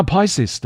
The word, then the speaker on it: Pisces: 然后再来是双鱼座，双鱼座是非常容易念错的星座，它的发音方式是 Pisces，Pisces，因为它跟一张纸、两张纸的 piece 很很像，哈，很容易念错。那 Pisces 的话。